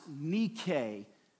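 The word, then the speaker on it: Nike (Νίκη): Uh, Nike, but they got the word, it's a Greek word that's actually pronounced Nike, which means victory.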